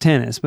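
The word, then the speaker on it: Tennys: With American pro tennis player, Tennies. – See? – Pronounced tennis. – You see how you struggled, though? Because it was... Anyway, it's pronounced tennis.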